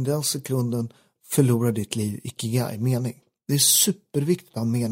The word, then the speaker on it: ikigai: men det är ikigai heter det. Det är aldrig... Ikigai, eller vad sa du för något? – Ikigai, nej, ikigai.